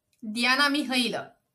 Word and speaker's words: Diana Mihăilă: How do you how do you say your your name in Romanian? Diana Mihaila. – Okay, but not Diana or Diana. – You can say Diana, it's fine. Okay.